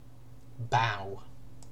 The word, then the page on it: bow: British English